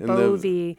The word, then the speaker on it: Bowe: B-O-W-E. It's and the a German v is, pronunciation. Bovee. Yeah, like Volkswagen.